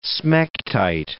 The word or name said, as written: smectite